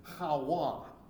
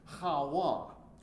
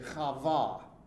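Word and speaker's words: Hawa (חוה): First off, I hate to tell you, but that's not how you say her name. It's how we say it in English, but that's not at all the way it comes out in Hebrew. The Hebrew, are you ready for this? Her Hebrew name is Hawa. Hawa. Or another variety of pronunciation would say Chava.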